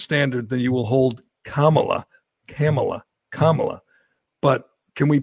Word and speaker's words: Kamala: Camel, Camel, Cam, Camilla.